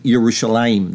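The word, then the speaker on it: Yerushalayim (ירושלים): meaning Yerushalayim, the way they pronounce it today.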